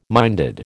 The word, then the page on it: minded: American English